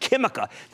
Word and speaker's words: Química: But Sociedad Química, which is Q-U-I-M-I-C-A, but it's pronounced Química,